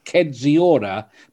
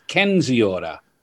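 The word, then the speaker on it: Kędziora: There's another player whose name looks like Kedziora, but should be pronounced Kenziora.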